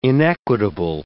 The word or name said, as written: inequitable